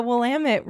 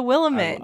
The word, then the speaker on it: Willamette: And Nicolas Cage even pronounces the Willamette River correctly. He doesn't call it the Willamette.